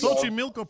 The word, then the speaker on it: Xochimilco: Xochimilco pronunciation.